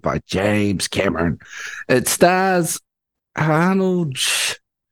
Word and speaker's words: Arnold Schwarzenegger: Arnold Schwarzenegger.